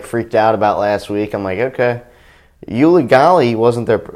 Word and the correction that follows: Uiagalelei: How do you say his name? – I think it's Uiagalelei. – Yeah, Uiagalelei.